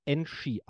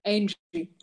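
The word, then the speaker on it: Enxhi: Also das spricht sich Enschi aus. – Angie, genau, richtig. – Angie, Angie wie die. Angie. Wie die An Angela.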